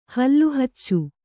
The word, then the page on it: ಹಲ್ಲುಹಚ್ಚು: Kannada